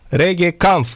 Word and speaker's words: Regenkamp: Am zis cu N înainte? Regenkamp, da, domne, așa este, aveți dreptate. Dacă am mai adăugat un N la mijlocul, cred că mi-a scăpat din pronunție. Dar este Regenkamp, aveți perfectă dreptate.